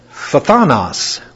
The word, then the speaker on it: phthanos: It's phthanos. You got to get an f and a th there together before you get any vowels. Thanos.